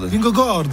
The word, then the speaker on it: Vingegaard: Vingegaard. – Oui, tout à fait. Vingegaard.